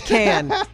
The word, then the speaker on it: Cannes: Cannes. Cannes is how you say it. – Cannes Film Festival. – No S. – Oh, well, then what? There's an S.